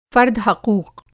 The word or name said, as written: फ़र्द-हक़ूक़